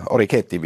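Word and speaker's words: Orecchiette: Da äh, es die besten Orecchetti, wie ich heute gelernt habe, ist die richtige Aussprache.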